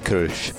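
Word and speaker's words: Kölsch: It's pronounced Kolsch. – Yeah, it's Kolsch.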